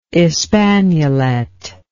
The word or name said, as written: espagnolette